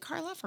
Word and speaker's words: Karloff: Like you'll take Boris Karloff. Is that a person? Mm-hmm. Boris, is it Karloff or Carlisle? – Oh my God. Boris know. Karloff. – I said it right the first time.